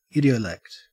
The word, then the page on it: idiolect: Australian English